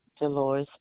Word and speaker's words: Delores: Dolores Brantley. – Could you spell that first name? – D-E-L-O-R-E-S.